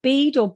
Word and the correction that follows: Bide: Bide? I think. BIDE.